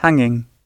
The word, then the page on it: hanging: British English